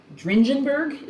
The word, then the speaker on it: Dringenberg: I think I'm going to mispronounce this guy's last name. I always do. Mike Dringenberg? Is it Dringenberg? – I think it's Dringenberg. – Dringenberg. – I think it's a hard G, but I don't know.